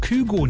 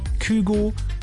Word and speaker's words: Kygo: Und der sagt, man kann ihn auch Kygo nennen. Kygo, Kygo, beides ist richtig.